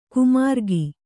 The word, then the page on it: ಕುಮಾರ್ಗಿ: kumargi